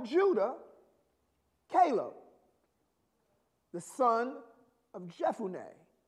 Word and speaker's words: Jephunneh: Jephunneh. Jephun. That PH is an F. You know, English is weird. They could have just put an F in there. Would have been so much easier. Jephunneh.